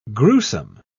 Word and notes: grewsome: British English

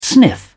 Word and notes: sniff: British English

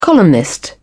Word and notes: columnist: British English

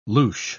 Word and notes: louche: British English

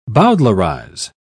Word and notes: bowdlerise: British English

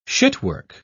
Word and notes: shitwork: British English

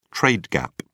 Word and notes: trade gap: British English

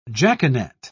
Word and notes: jaconet: British English

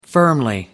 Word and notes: firmly: American English

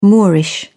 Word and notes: moorish: British English

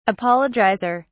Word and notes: apologizer: British English

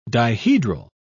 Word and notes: dihedral: British English

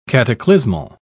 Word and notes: cataclysmal: British English

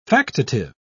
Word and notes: factitive: British English

